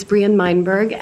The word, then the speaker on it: Meinberg: My name is Brian Meinberg, M-E-I-N-B-E-R-G.